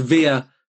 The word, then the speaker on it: Villa: And it's Via, I'll put the links in my show notes, yes. – But it's Via is spelled V-I-L-L-A. – That's so, correct.